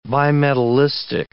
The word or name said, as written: bimetallistic